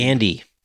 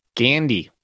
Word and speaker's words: Gandy: Uh, Devin Gardry, Gordry, am I saying that right? I can't read my own handwriting. Gandy. Gandy. There is no R in there.